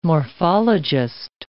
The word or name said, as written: morphologist